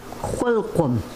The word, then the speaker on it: Kwilkum: Kwilkum. – Kwilkum. – You always emphasize the underlined X.